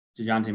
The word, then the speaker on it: Dejounte: You pronounce it like a mustard. DeJounte Murray. Is right. Deontay a mustard? – No, you should pronounce it like the mustard, but Kyle doesn't.